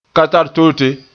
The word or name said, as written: katartote